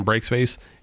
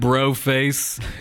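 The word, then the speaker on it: Breax Face: One thing to mention is when we're talking Breaks Face, it is spelled B-R-E-A-X face. – Face. Yes. – That's true. And yeah. It is not bro face. It is not French. It is not fancy.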